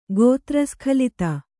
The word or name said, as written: ಗೋತ್ರಸ್ಖಲಿತ